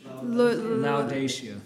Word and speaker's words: Laodicea: How do you say this church? – Laodicea.